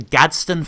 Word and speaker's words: Gadsden: The Gadsden flag? – That's how I would pronounce it for sure.